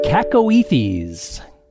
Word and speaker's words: cacoethes: And the word sent in by listener Marlene is cacoethes. Cacoethes. That's spelled C-A-C-O-E-T-H.